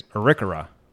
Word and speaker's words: Arikara: When you read it, I said Arikara. Okay. – Mm-hmm. – The pronunciation is Arikara.